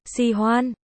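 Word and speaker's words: si hoan (喜欢): Hỉ hoan phu phu, hỉ hoan, si hoan, yêu thích, từ này đọc là si hoan.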